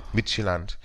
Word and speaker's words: Midtjylland: Ich hoffe, ich habe es richtig ausgesprochen. – Midtjylland.